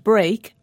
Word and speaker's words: break: And speak and break are pronounced differently, although they both have E-A-K at the end.